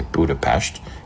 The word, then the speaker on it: Budapest: And yes, I'm saying it the proper way when I say Budapest, even though it's spelled Budapest.